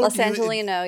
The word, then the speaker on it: Los Angeleno: Is it Los Angelino? – Los Angelino, yeah.